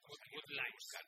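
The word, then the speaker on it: Wadleigh: A ver, porque este es difícil pronunciar. Michael Watlake. – Watlake. – Escrito W-A-T-L-E-I-G-H, ¿vale? – Watlake.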